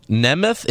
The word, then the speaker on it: Nemeth: Donald Nemeth. It's spelled N-E-M.